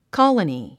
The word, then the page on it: colony: kɑ’ləni; コォロニィ